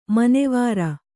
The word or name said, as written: ಮನವಾರ್